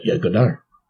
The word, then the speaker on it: Godard: Yeah, Godard. Well, it's spelled with a D. I'm just doing the French pronunciation.